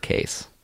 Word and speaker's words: Kees: This closing one is written by, his name is spelled K-E-E-S, but he says it's pronounced Case.